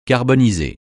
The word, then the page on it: carboniser: francais